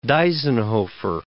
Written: Deisenhofer

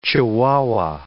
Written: Chihuahua

